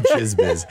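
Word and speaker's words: Djizz Biz: It's spelled DJIZZ. – All right. Silent D. – It's called Jizz Biz.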